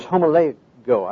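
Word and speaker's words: homologeo: Now then, the word for confession in the Greek New Testament is homologo. I don't know, that's not the right pronunciation. It's H-O-M-O-L-O-G-E-O. The literal meaning is to agree with God.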